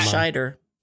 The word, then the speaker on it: Scheider: How do you pronounce his name? – Scheider.